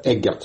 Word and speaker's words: Эггерт: Номер два, моя фамилия произносится Эггерт с ударением на первый слог.